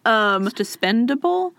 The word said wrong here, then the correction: dispensable: Did I say that wrong? – No, dispensable's right. – Okay. – Because you can dispense of him. Yeah. Dispendable, I think, is wrong.